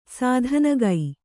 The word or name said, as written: ಸ್ಥಾನಿಕ